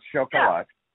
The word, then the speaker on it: Chocolat: I know there's a film called Shuck-a-La, Chocolat, pronounced Shuck-a-La.